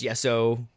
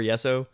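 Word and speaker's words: Jesso: Yesso. Is I pronouncing his name right? Yesso or Yesso? – I'm going to go with Yesso.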